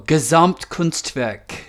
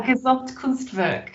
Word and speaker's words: Gesamtkunstwerk: It's a Gesamtens. Work. – Oh, Gesamtkunstwerk. – Yeah, Gesamtkunstwerk. I think that applies to the Bowie project.